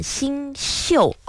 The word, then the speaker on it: 星宿: 其实这个宿字啊，它就是和宿舍的宿字是同个字。不过呢，我们在这里古人的星座呢，其实应该念成星宿啊。